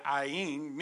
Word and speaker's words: Ayin: And interestingly enough, the Hebrew word for that 16th letter of the alphabet, Ayin, means eye, or to see. And it's a silent letter. It's not pronounced.